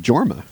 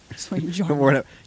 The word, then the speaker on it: Jorma: Yorma Taccone, is that how you say it? – I think it's just Jorma. I don't think he's that Swedish. – Jorma Taccone.